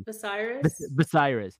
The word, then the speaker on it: Viserys: Viserys.